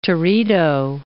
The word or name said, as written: teredo